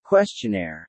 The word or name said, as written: questionnaire